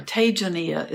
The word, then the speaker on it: Tejaniya: I hope you go to his website, and it is Tejaniya, I'm sorry, Tejaniya is the pronunciation, Tejaniya.